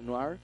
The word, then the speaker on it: noir: Oye, bueno, está escrito por Ed Brubaker, que es un gringo de 47 años, es especialista en cómics de crimen, onda noir, que se pronuncia, que se escribe noir. – ¿Y se pronuncia? – Noir. – Noir. – Noir en francés y noir en inglés le dicen noir.